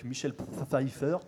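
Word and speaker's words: Michelle Pfeiffer: Ou Batman, le défi, en 92, avec Michel Pfeiffer.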